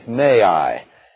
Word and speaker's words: magi: and it goes out into the margin telling us how we ought to pronounce it by saying may I.